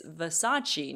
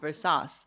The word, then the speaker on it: Versace: It's Versace, not Versace.